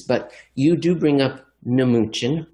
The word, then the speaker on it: Mnuchin: Jeff, you don't often see English words with the M and N in the beginning. And when you do, the M is silent, mnemonic. Yet for some reason, we call the former Treasury Secretary Mnuchin.